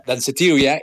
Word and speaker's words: Danceteria: Este tema en español es Dancetería, aunque se pronuncia Danceteria, el antro o en el club donde Madonna debutó cuando tenía solamente 19 años de edad.